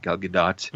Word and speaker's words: Gal Gadot: Yeah, Gal Gadot. Gal Gadot. – Yeah. – Apparently that's the way they pronounce it. Gal Gadot.